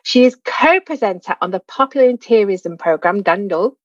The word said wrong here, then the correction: Lle Bach Mawr: and Wow. Mandy, did I say that right? – Almost. Lle bach mawr. – That's it.